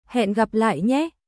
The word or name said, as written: Hẹn gặp lại nhé